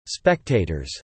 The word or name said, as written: spectators